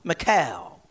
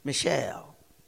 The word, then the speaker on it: Michal: David fled from Saul and went home to his wife, Michal. Some may pronounce it Michelle. David and Michelle.